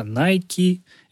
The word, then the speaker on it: Nike: Тема произношения — это на самом деле отдельная тема, потому что если прям совсем правильно, то да, это Nike, это Adidas, это Э, Сокони.